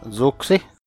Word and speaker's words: Zuxi: I can be found from BoardGameGeek under username Zuxi, Z-U-X-I.